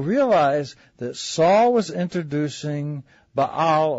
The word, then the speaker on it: Baal: And his real name, believe it or not, was Ish, and I guess in English you pronounce it, and help me, Soapy, is it Baal? Ishbaal? It's Baal.